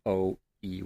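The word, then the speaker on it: Ōe: I'm not sure if I'm pronouncing that right. It's O-E with a dash over. – Oe. – So I said it right. – Yeah. Sounds about right.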